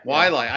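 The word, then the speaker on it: Waialae: Wale Country Club. It's, and I butchered the pronunciation. – Wailai.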